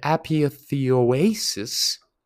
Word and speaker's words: apotheosis: the apotheosis. I always mispronounce that word. Apotheosis.